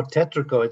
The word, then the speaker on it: tetrico: Tetrico, how do you pronounce that? – Oh, tetrico. It's tetrico. It's Portuguese.